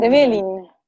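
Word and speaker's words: Trevelín: Estuve googleando bien, bien dónde está, ¿cómo se dice? ¿Trevelin o Trevelin? ¿Dónde va como el acento? – Trevelin. – Trevelin, ok. – Trevelin, okay. Sí.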